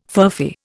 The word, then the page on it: furphy: Indian